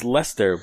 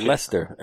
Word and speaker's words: Leicester: Right next door to Worcester is Leicester, but it's spelled Leicester. – Leominster? – Leicester. – Not Leominster. Gotcha. Leicester. L-E-I-C-E-S-T-E-R. – Leicester. – Leicester. – It's a Leicester or Leicester? – It's Leicester is how you pronounce it.